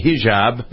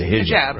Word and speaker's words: hijab: hijab. Is that how you pronounce it? It's a hijab, right? – Hijab, yeah.